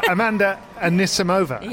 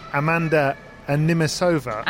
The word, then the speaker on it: Anisimova: And that is to say that last week we persistently mispronounced the name of Amanda. Take it away, David. – Oh, no. Amanda Anisimova. – Yeah. – Who we were calling Amanda Animisova.